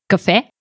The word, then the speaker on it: cafè: La paraula cafè porta un accent obert per indicar que la seva pronunciació no segueix les normes normals de la llengua catalana per a les paraules acabades en E. En català, les paraules acabades en E gairebé sempre es pronuncien com a E tancada, però en aquest cas es pronuncia com a E oberta.